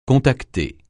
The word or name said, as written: contacter